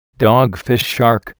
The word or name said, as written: dogfish shark